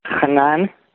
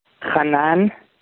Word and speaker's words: Hanan: It's Hanan from Gainesville. – Who is it? Oh. – Hanan. From Gainesville, from Alachua. – Say that name again. Spell it for me. – Hanan, H-A-N-A-N.